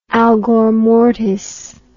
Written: algormortis

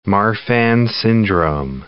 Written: Marfan syndrome

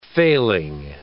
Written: failing

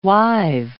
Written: wive

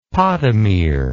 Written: podomere

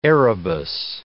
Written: Erebus

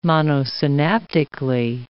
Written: monosynaptically

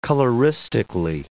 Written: coloristically

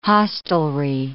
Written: hostelry

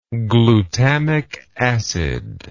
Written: glutamic acid

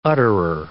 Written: utterer

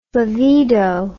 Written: flavedo

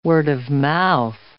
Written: word-of-mouth

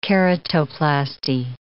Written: keratoplasty

